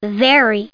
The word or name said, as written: very